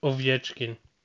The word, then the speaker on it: Ovechkin: Alex Ojev. Ojev. Wie spricht man ihn richtig aus? – Ovechkin.